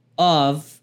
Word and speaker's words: of: Preste atenção aqui no of é com som de V. Esse of e não F, porque OFF quer desligar ou desligado, esse sim quer dizer off, né? Você vai pronunciar com som de F.